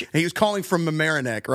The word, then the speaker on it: Mamaroneck: And so that's the only thing I know about Mamaroneck, New York.